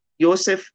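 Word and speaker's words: Yosef (יוסף): It is pronounced Yosef in Hebrew, meaning the Lord increase.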